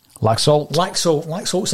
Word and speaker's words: Laxalt: Laxalt signs on Monday, and you go on social media, get him in for the Rangers, get him in.